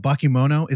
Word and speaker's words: Bakemono: Again, we're talking to Doug about Bakemono.